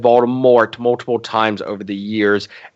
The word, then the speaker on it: Voldemort: I mean, they allude to it, but at the same time, like, Voldemort, because I got to pronounce it that way, because apparently, according to Rowling, we've been pronouncing it wrong. You have to pronounce the T at the end.